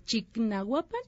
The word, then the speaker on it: Chignahuapan: Y bueno, quisiera platicarles acerca de Chinahuapan. No sé si han escuchado, no sé cómo se pronuncia, Chignahuapan. Chignahuapan. Chignahuapan.